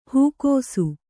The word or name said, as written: ಹಾಕಿಸು